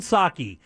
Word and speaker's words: sake: So this is a website called Urban Sake, and it has all the rules and regulations of how and what's supposed to happen. – Jimmy, before you go any further, apparently we've all been saying it wrong.